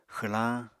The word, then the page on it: xwlañ: Apatani